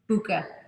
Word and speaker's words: Bucca: Bucca.